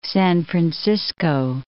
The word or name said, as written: San Francisco